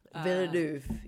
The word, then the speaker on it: Villeneuve: I was going with Denis Villeneuve because he's French. – Villeneuve.